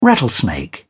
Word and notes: rattlesnake: British English

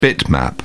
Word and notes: bitmap: British English

